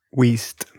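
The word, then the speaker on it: Wiest: Like, Stalker Channing and Diane West, is her name pronounced West or Weist? – Weist. Diane Weist.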